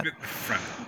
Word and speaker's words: Fremen: Is it the Fremen? How do you pronounce that? – Fremen. – Yeah, they call it Fremen in the movie.